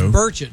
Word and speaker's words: Burchett: Tim Burchett joins us after the top of the hour at 8 o'clock, around 8:05-ish or so. – So it's Burchett, not Burchette. – It's Burchett, yeah.